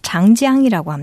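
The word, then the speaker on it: 장장이라고 (长江): 장강은 중국어 발음으로 장장이라고 합니다.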